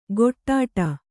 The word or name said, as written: ಗೊಟ್ಟಾಟ